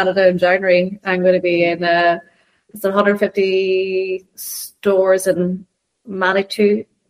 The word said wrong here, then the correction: Manitoba: How do you pronounce it, Colin? – Manitoba.